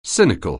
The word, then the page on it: cynical: anglais